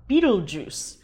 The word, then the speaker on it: Betelgeuse: I will be trying the 2019 Rosé of Pinot Noir California that's called Beetlejuice.